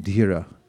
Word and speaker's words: Dhira: It's spelled D-H-I-R-A, Dhira. You pronounce the H, T, like that.